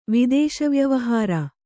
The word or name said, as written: ವಿದೇಶವ್ಯವಹಾರ